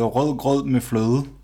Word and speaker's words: rødgrød med fløde: Øh, det, det hedder rødgrød med fløde.